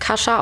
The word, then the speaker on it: Kasia: Ich glaube, sie wird Kasia ausgesprochen, der polnische Name Kasia trifft. Wird aber Kasia geschrieben, also K-A-S-I-A.